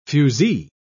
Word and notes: fuzee: British English

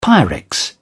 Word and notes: pyrex: British English

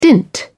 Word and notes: dint: British English

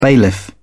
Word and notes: bailiff: British English

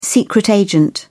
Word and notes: secret agent: British English

